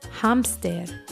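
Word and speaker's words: hámster: And remember, even though this is a cognate and it's spelled the same way, it's pronounced a little differently. So for hamster, we say hamster.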